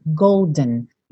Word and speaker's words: golden: Olha que interessante esse olden e golden. Isso é um som bem americano mesmo, essa pronúncia, olden, golden. Pode até você pensar nesse D como rararã também tá? Porque esse E não é pronunciado. Você não fala Oden e nem Golden. É Oden, Golden, dun, dun. É só o um, um necessário, assim, é bem nasal esse som.